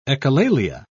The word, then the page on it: echolalia: British English